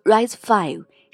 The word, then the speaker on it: RISC-V: RISC-V 读作 RISC-V，是二零一七年图灵奖得主 David Patterson 发起并倡导的开放指令集架构。